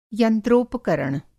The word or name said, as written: यंत्रोपकरण